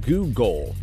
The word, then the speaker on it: googol: The name Google originated from the misspelling of Google, which G-O-O-G-O-L, which refers to a super long number. Well, one followed by 100 zeros is called Google, G-O-O-G-O-L, and it's pronounced the same way.